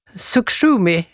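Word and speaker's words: Sukhumi: Sukshumi, ursäkta uttalet om det är fel. – Mm, det var jättebra.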